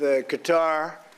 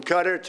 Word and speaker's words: Qatar: And with Bahrain and with Qatar, there's six different ways of pronouncing that, from Qatar to Qatar, right? There's many, many ways of pronouncing it. All of them are acceptable.